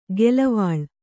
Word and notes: ಗೆಲವಾಳ್: gelavāḷ

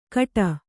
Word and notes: ಕಟ: Kannada

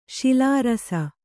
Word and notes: ಶಿಲಾರಸ: śilārasa

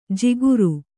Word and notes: ಜಿಗುರು: jiguru

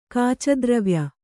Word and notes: ಕಾಚದ್ರವ್ಯ: kāca dravya

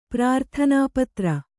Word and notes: ಪ್ರಾರ್ಥನಾಪತ್ರ: prārthanā patra

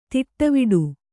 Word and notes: ತಡೆವಡೆ: Kannada; taḍevaḍe

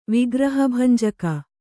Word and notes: ವಿಗ್ರಹಭಂಜಕ: Kannada

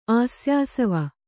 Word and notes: ಆಸ್ಯಾಸವ: āsyāsava

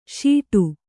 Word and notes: ಶಾಟಿ: śaṭi

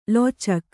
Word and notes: ಲೋಚಕ: lōcaka

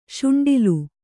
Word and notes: ಶುಂಡಿಲ್: śuṇḍil

